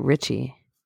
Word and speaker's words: Ricci: It's R-I-C-C-I. – I thought you pronounced it Richie.